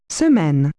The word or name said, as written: week